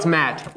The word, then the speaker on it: matte: That's how you pronounce it. I always, my whole life, thought it was matte. – Matte? – It was matte. – There's no accent mark. – There's an E at the end of the word.